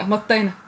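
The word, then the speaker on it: mottainai: So I think it's a really good word, motanai. Am I saying it right? Yeah, motainai. Motainai.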